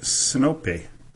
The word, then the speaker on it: Sinope: On November 30th, 1853, Admiral Nakhimov ordered an attack on the Turkish fleet at, and now there's a number of different pronunciations of this town, but I'm going to go with the one that seems to be more popular, and it was the Turkish fleet at Sinope on the Anatolian coast.